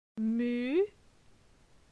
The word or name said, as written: Mu